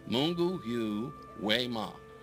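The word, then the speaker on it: Mungu yu wema: Mungu, and that Y-U is pronounced U. Mungu yu we ma.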